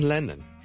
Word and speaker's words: Lennon: 第一个字咧就系、是、人名嚟嘅，John Lennon，John Lennon，John Lennon，一个英国嘅好出名嘅歌手同作曲家啦，Lennon，L-E。